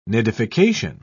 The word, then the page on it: nidification: British English